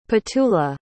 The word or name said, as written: petula